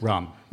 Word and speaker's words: Röhm: Well, in Rome, is that how it's pronounced? – Rom. – Rom. – Ernst Rom.